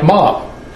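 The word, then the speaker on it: ma (马): Turns out when you pronounce this in Chinese, it sounds like ma.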